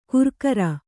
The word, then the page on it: ಗುರ್ಕಾರ: Kannada